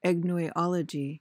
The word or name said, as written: agnoiology